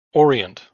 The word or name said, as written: Orient